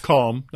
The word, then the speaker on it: calm: Calm, not cam. – Yes, calm is yes. the proper way to say it.